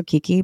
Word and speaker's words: Kiki: But my nickname is also Kiki, but K I K I.